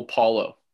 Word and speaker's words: Paolo: But I'm hoping like a rivalry will soon form between Chet and Paolo, or Paolo. – I think it's very simple, Paolo. – Okay, Paolo. It doesn't have that Portuguese, Italian accent.